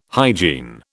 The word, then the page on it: hygiene: British English